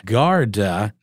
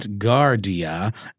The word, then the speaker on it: Garda: It's Garda, not Gardia,